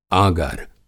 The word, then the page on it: आगर: Hindi